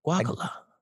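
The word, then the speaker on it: guácala: Oh wait, how you pronounce it? – Gwakala. – Got you. – It means like ill in Spanish, where I'm from. – Gwakala.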